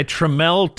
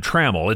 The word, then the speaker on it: Tramell: Seth Milchick is being played by Tramell Tillman, one of the breakout stars of the series. I always want to Midwesternize his name and call him Tramell. It's pronounced Tramell.